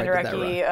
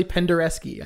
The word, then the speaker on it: Penderecki: I'm sure Penderecki, I did that wrong. It's actually Penderecki. I looked this up.